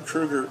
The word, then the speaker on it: Krueger: And also, you guys have another great writer, Jim Krueger. Am I pronouncing his last name? Absolutely. Mm-hmm. Jim Krueger.